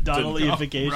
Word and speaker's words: Donnellification: I think we, Jesse and I played there once prior to its, like, Donnellification. Because is that how you say it? – Donnellification. – Donnellification. – Right.